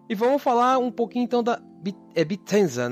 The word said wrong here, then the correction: Bethesda: né? Que fala? – Bethesda. Bethesda. Bethesda.